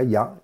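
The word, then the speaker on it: Ya (יה): Hay un nombre sagrado a meditar que son las letras Yud Hei o Ya, que se pronuncia Ya, y que son las primeras dos letras del nombre de Hashem.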